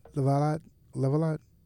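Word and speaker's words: de Lavallade: Maya Angelou, Carmen de Lavadad, Merce, I might have said it wrong. Merce Cunningham. – What does it say? – Lavalot. – Okay, Lavalot, sure.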